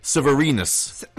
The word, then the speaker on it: Severinus: His government name is, and I hope I'm pronouncing this correctly, Anicius Manlius Severnius Boethius. – Anicius Manlius Severnius. – Severnius. – Oh, yeah, I'm mispronouncing it. Severinus. I've been mispronouncing that, actually. I just realized.